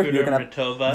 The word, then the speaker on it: Kudermetova: Kudermetova.